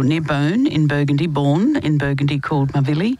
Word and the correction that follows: Mavilly: Mavi, I should say. That's the correct pronunciation.